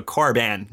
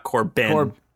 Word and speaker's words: Corban: And this comes from Corbin, and he says, Cor, like corn, and ban, with a long A, ban. So Corban, not Corbin.